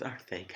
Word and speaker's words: Arcega: He came out the other day and he's like, no, it's pronounced Arcega. – I think we talked about it in Spanish. – It's Arcega. Arcega. But it sounds like he has a lift.